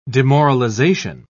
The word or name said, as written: demoralization